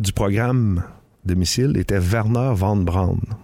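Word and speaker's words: Wernher: Non, Werner, c'est un W. – Ah, oui, c'est vrai. – Je vais me reprendre, c'est Werner von Braun. – Oui, ouais, ton allemand n'était pas très bien prononcé.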